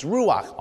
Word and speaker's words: ruach: And I'm not sure if I'm saying that right in Hebrew, but it's ruach, R-U-A-C-H, ruach. That's the word for spirit in Hebrew.